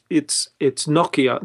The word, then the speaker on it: Nokia: But it's Nokia. Nokia is like any Finnish word I learned. The accent is on the first syllable. So yeah. It cannot be Nokia.